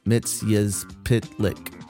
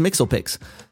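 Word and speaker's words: Mxyzptlk: and Mr. Mixelplix, pronounced Mitzyazplitlik. Mitzyazplitlik in Superman, the animated series. He reprised his role as... I thought it was Mixelpix. He reprised his role as Mitzyazplitlik. That's how it's spelled out phonetically, in Lego Batman 3, Beyond Gotham, Justice League Action, and Lego DC Supervillains.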